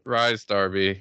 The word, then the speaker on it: Rhys Darby: like Rhys Darby, is that how you pronounce it? – Rhys Darby, yeah, Rhys, that's Darby.